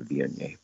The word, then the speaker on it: Viognier: It's spelled V-I-O-G-N-I-E-R. Viognier is how it's pronounced.